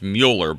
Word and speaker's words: Mueller: I do want to ask, is it pronounced Mueller or Mueller? Mueller, because where I come from, it's Mueller, but I just want to make sure I'm saying it right. – It is Mueller. That's right. You got it right.